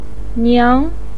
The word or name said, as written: niang2